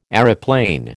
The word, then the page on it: aeroplane: American English